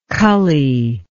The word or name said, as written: cully